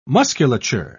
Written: musculature

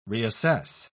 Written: reassess

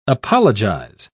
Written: apologize

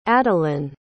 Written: adelynn

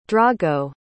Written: drago

